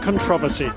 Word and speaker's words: controversy: King Abdullah may not be head of an evil empire, but his visit is mired in controversy. – Love the way the Brits say controversy. Controversy.